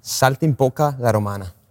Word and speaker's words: Saltimbocca alla Romana: Saltimbocca alla Romana.